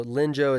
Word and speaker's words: Linzhou: So Linzhou is L-I-N-Z-H-O-U, pronounced Linzhou. I had been calling it Linzhou until I got to China and realized that I was an idiot.